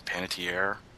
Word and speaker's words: Panettiere: We would end up with Hayden, how do you say her name? Panettiere? – Yeah.